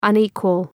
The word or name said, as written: unequal